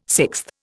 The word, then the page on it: sixth: Indian